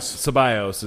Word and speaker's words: Ceballos: Ceballos is how it's pronounced, with 2.